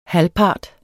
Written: HALVPARTEN

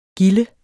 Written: gilde

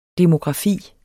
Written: demografi

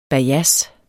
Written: bajads

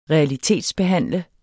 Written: realitetsbehandle

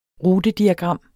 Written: rutediagram

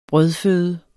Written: brødføde

